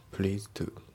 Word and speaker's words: pleased: で、発音ですけど、 pleased の D がね、抜けますね。 pleased to。